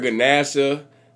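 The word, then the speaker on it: Ganasha: But I done heard Ganesha trying to pronounce it. My boy said Geneva. – So it ain't spelled G-E-N-E-V-A? – Bro, ain't no. It's a G-A-N-A-S-H-A.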